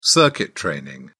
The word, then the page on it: circuit training: British English